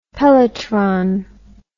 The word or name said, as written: pelletron